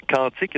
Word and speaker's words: quantique: Alors voilà, on va garder la même prononciation que pour le mot aquarium. Sans ça, les gens ne savent plus quand on dit quantique, ce qui veut dire le champ, là.